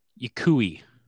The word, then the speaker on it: Yokoi: Sochi Yokoi. Yeah, like Y-O-K-O-I.